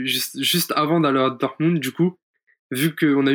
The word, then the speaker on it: Dortmund: et après, donc là, tu rentres de Dortmund, euh, Dortmund, pardon, c'est pas facile à, prononcer, hein. Dortmund,